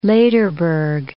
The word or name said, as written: Lederberg